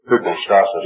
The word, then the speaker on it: hypostasis: The man who has faith is hypostasis.